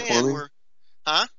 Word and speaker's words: terraforming: Have you heard of terror reformation? And I'm probably saying it wrong right now, but we have this plan. – Terraforming? – Terraforming? Terraforming, that may be – we have this plan where we can go to Mars and we think we can change the atmosphere there.